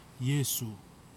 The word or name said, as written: yesu